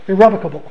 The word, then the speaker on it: irrevocable: One of the brethren in Detroit always used to pronounce it irrevocable. Or no, he called it irrevocable. Irrevocable. And I looked it up in the dictionary, and he was right. I didn't realize that. I always looked at it as irrevocable. But it's actually irrevocable, and I think it's either the first or the second way of pronouncing it.